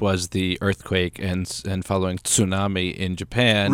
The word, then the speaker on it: tsunami: Tsunami, you pronounce it so well. – Thank you, yes. I learned that from George Takei on the Howard Stern Show. He taught everyone how to say it properly.